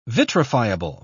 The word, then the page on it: vitrifiable: British English